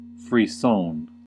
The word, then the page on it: frisson: American English